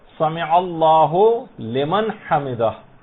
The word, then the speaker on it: سمع اللہ لمن حمدہ: عام طور پر لوگوں کو دیکھا گیا سمع اللہ لمن حمدہ یہ پڑھتے ہیں، اس کو ہولمن نہیں ہے، سمع اللہ لمن حمدہ، اس میں عین بھی